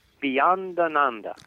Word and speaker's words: Beyondananda: Bhiyanananda. – Bhiyanananda. – Ananda. All the Swamis have Ananda after their name, and I found out that Ananda means bliss.